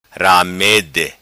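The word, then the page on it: Ramede: patua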